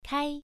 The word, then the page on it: kai: 1